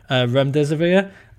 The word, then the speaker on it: remdesivir: And that was aided then by Gilead's testing for, and I'm sure I'm going to get this pronunciation wrong, but remdesivir.